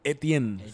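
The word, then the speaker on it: Étienne: San Etienne.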